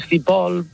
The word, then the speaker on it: Piscipool: Piscipool. – Claro. Claro, bueno, correctamente, lo has dicho muy bien, correctamente en inglés las dos O's se pronuncian como si fuese una U.